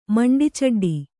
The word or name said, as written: ಮಂಡಿಚಡ್ಡಿ